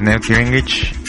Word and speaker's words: Newt Gingrich: Así es, Newt Gingrich.